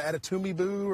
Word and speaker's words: Adetomiwa: I think I said Toomey, so it's more of an Adetumibu or something like that. So I butchered it very bad the first time.